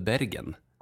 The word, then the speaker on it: Bergen: Och som inte ens uttalas Bergen utan som väl Bergen.